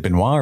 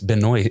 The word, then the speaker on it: Benoist: Is it Benoit or Benoist? – I think it's Benoist. It's not what it looks like to me reading like French. I think it's like Benoist.